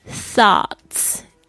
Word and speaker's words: Thoughts: G-A-R-D-E-N 花园 Your thoughts 这个 T-H-O-U-G-H-T-S 这个特别注意一下，这个 T-H 是 th, 这个两排牙齿轻轻咬住这个前这个舌尖的这个部分 th, 发出这个无声的这个 th 的音 Thoughts G-H 不发音 T-S 发 th 的声音 Thoughts Are the seeds 种子 S-E-E-D-S 特别注意一下 D-S 的发音，